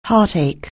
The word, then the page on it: heartache: anglais